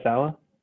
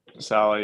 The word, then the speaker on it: Saleh: Am I saying it right? Saleh. – Saleh, yeah, I love it.